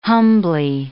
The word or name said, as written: humbly